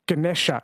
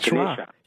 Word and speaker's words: Ganesha: Or they call the elephant god Ganesha in the south, Ganesh in the north. They delete the A's. – So the A at the end is pronounced like a schwa, Ganesha.